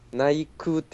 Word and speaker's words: 内宮: あれ、内宮じゃないですから。 – なんだっけ？ – 内宮って読みますから、うん、あれ。 – おお。 – その手紙ちゃんと調べるのがええで。 – 内宮下宮って読みますから。